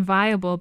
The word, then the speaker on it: Vayable: Another website called Viable, but it's spelled V-A-Y-A-B-L-E.